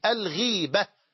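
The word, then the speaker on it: الغِيبة: إلى هنا الكلام يعرف عند النحويين وعلماء اللغة بالغيبة، بالغيبة، والغيبة غير الغيبة، لأن بعض إخواننا ينطق الغيبة غيبة، وهذا لا يصح، بل الغيبة وهي ذكرك أخاك بما يكره بكسر الغين، بكسر الغين، الغيبة.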